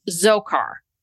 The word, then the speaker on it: Dzhokhar: and then how do you pr- pronounce the younger brother's Dzhokhar? Dzhokhar, because the D is silent.